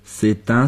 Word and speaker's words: c'est un: C'est un stylo. – 如果是阴性名词，如 clé, 就用 c'est une. – C'est une clé. – 注意 c'est un, c'est une 都需要连诵，读成 c'est un, c'est une.